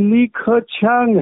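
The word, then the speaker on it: Li Keqiang: इनका उच्चारण लीख छांग है वहां पे बोलते हैं लेकिन ऐसे लिखा जाता है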